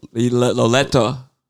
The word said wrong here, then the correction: Liotta: Liotta. Ray Liotta. – All right.